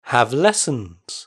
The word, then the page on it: have lessons: British English